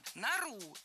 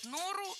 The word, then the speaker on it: нору: «Нору» – это правильно, а вот «нору» все-таки говорить не стоит. Перед нами существительное, в котором ударение в единственном числе падает на окончание, а во множественном – на корень.